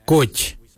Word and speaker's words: goodj: Garli is spelled G-I-I-L-Y-I, garli. For the traditional stone axe, we say goj, goj. Goj is spelled G-O-O-D-J, goj.